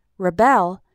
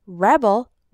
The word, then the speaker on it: rebel: And rebel, with the stress on the first syllable, is a noun, meaning a person who rebels.